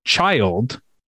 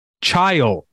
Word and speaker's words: child: I had put two syllables for child, a word that I, to this day, I think still say as if it's a two syllable word. – Yeah. – Got my quiz back and it said I was wrong. And I like went up to her desk and I was like, Mrs. Petrillo, I think, You made a mistake. You said that I got this wrong. She was like, no, that's right. It's one syllable.